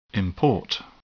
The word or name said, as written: import